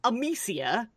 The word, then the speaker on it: Amicia: So the main story is you're following Amicia, rhyming with emaciated.